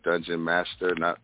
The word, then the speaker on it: Dungeonmasta: Well, okay, for people that has brain capacities that can actually think, the pronunciation of the name is Dungeon Master. So when you spell it, it's DUNGEONMASTA.